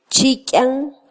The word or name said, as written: chikyan